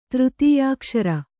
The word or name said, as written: ತೃತೀಯಾಕ್ಷರ